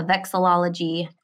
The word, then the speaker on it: vexillology: And then vexology, am I saying that right? Vexillology. – Vexillology.